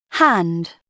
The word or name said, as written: hand